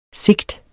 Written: sigt

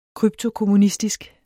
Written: krypto-

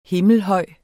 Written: himmelhøj

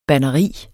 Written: banderi

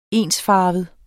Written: ensfarvet

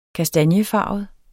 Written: kastanjefarvet

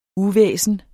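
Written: UVÆSNET

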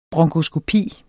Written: bronkoskopi

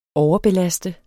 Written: overbelaste

